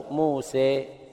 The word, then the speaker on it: موسى: صحف إبراهيم وموسى هكذا موسى كما تقول سيبويه نفس النطق سيبويه أو موسى تقريبا يعني طبعا لفظ سيبويه مش في القرآن ولكن هو كما تنطق هذا اللفظ موسى عيسى السلوى